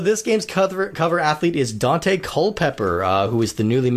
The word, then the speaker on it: Daunte: And it's spelled D-A-U-N-T-E, which makes it even cooler. It's Dante. – Dante.